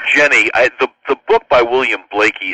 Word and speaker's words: Blaikie: But the book is called For the Work of the Ministry, and the last name is spelled B-L-A-I-K-I-E, William Blakey, B-L-A-I-K-I-E.